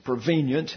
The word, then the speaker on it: prevenient: He celebrates over the prevenient grace, prevenient is the correct pronunciation, the prevenient grace of God through Christ.